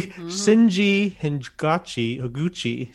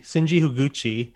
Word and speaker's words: Shinji Higuchi: That was a clip from the film Shin Ultraman, directed by, let me pronounce this correctly, Shinji Higuchi. Shinji Higuchi.